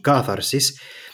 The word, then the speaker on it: katharsis: Whereas when that's brought forward into the light, then the possibility of cleansing, catharsis, or Greek pronunciation, katharsis, as facilitated ideally by drama, means maybe it's not necessary for those events to really happen.